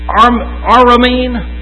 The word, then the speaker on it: Aramean: From the time that Jacob went down, that was the Armenian. How do you say that? My father was a wandering Aramean.